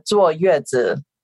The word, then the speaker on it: 坐月子: Yes, it's a 坐月子. So it's like 坐, and then 坐 means sit, and then 月子 means month.